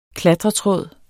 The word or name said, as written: klatretråd